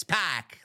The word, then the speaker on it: Pac: It's Pac, like a six pack.